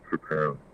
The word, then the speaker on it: Tappan: First of all, it's pronounced Tappan, not pronounced Tappan.